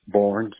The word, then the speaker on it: Barnes: Barnes, B-A-R-N-E-S. – Got it. And Julius, do you spell it J-U-L-I-U-S? – Yes.